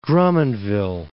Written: Drummondville

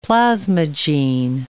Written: plasmagene

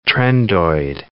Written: trendoid

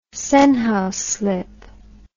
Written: Senhouse slip

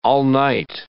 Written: all-night

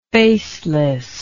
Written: facelessly

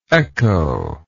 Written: echoless

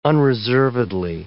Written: unreservedly

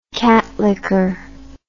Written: cat licker

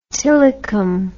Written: tillicum

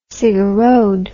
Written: cigarrode

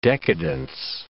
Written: decadence

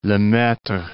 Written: Lemaitre

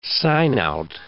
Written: sign-out

